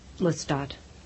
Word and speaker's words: Lestat: I just pronounce it Lestat. – Lestat. Lestat. – Mm-hmm. I made up the name, so that's the way I say it.